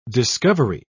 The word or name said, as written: discovery